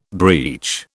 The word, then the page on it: breech: British English